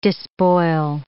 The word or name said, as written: despoil